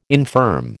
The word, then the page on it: infirm: American English